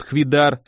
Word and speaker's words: Хвидар: Федор, себя он зовет Хвидар, с ударением на последнем слоге, сослуживец моего донора, проведать пришел.